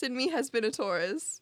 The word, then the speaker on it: Taurus: um, Taurus. Terrace. How do you say it? Taurus. Taurus. Taurus.